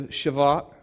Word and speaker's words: Shavuot: They had gone through the Shavuot. Okay, I actually said it right this time. Not Shabbat, but the Shavuot.